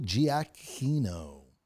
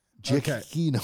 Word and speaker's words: Giacchino: Music by Michael Giacchino. – Well, something tells me you butchered his name. – Giacchino.